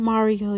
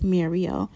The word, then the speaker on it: Mario: Not Mario, y'all. It's actually pronounced Mario.